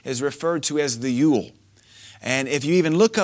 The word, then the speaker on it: Yule: And that they spelled it J-O-L, but we pronounce it Yule.